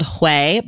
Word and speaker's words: Hue: The first one I'm going to start with, it's called Hue, but it's spelled H-U-E.